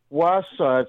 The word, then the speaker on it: Wausau: How do you say that? Wausau? – Wausau.